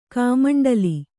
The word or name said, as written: ಕಮಂಡಲ